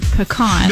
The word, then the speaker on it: pecan: Okay, but what I really need to know is how do you say the word pecan? – No, I was going to tell you, you said it right. – Correct. It's not pecan.